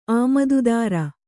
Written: ಆಮದುದಾರ

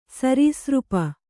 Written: ಸರೀಸೃಪ

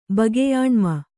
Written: ಬಗೆಯಾಣ್ಮ

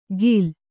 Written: ಖಿಲ

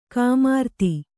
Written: ಕುಮಾರಿತಿ

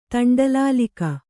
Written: ತಂಡಲಾಲಿಕ

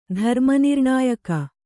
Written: ಧರ್ಮನಿರ್ಣಾಯಕ

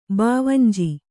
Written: ಭಾವಂಜಿ

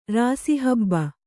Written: ರಾಸಿಹಬ್ಬ

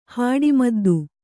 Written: ಹಾಡಿಮದ್ದು

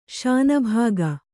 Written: ಶಾನಭಾಗ

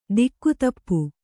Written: ದಿಕ್ಕುತಪ್ಪು

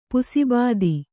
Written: ಪುಸಿಬಾದಿ

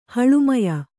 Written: ಹಳುಮಯ

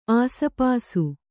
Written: ಆಸುಪಾಸು

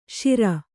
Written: ಶಿರ

